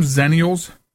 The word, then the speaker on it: Xennials: I didn't look up the pronunciation. I'm going to say Xennials. Barry, you ever heard this term, Xennials? – Yeah, I definitely have heard of that. – Okay, am I saying it right then? – I think so, yes.